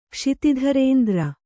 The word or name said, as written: ಕ್ಷಿತಿಧರೇಂದ್ರ